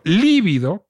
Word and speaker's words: líbido: Pero líbido, que es un adjetivo con B chica y con acento en la primera sílaba, una palabra esdrújula, significa, cree la gente, pálido.